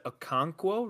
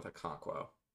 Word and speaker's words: Okonkwo: You said Okonkwo, right? – I believe so. Okay. That was a literary character in Things Fall Apart, a book we read in high school. So I think that's how you say it.